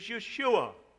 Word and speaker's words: Yeshua: The literal pronunciation of Jesus is Yeshua.